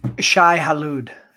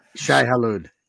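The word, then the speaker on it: Shai-Hulud: It's Shai Halud. – Shai Halud.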